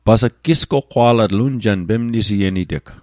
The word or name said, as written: goqqwa'latl